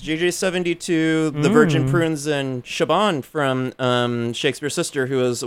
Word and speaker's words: Siobhan: Siobhan, but it's Siobhan. – Siobhan. Okay. Siobhan.